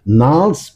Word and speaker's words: Gnarls: Das ist Gnarls, Gnarls, Gnarls. Das G spricht man nicht. Gnarls Barclay mit Crazy.